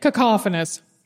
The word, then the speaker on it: cacophonous: Cacophonous. How do you say it? – Cacophonous. – Cacophonous. There you go. Or cacophonous, if you want to say it the Minnesota way.